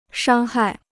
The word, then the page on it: 伤害: shāng hài